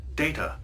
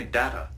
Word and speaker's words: Data: It is pronounced Data. – Oh? – You called me Data. – What's the difference? – One is my name. The other is not.